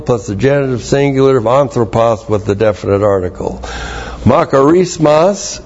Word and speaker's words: anthropos: And anthropos is the generic term for mankind. A-N-T-H-R, long O-P-O-S.